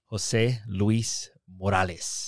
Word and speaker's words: Jose Luiz Morales: Did I say it right? Because I feel like that was kind of like the American way to say it. – Yeah. You said it as best as possible. – As best as I could. Tell me the right way to say it. – Jose Luis Morales.